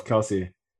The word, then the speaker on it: Kelce: Because I also heard Travis Kelce call himself Kelce, although he's not German. – That's a valid point. So I think because it's R-E-I, it's Re.